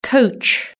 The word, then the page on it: coach: British English